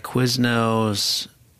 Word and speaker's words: Quiznos: At Quiznos, no. – Wait, I'm sorry, how do you say it? – Quiznos. – Okay, it sounded like you said Quiznos.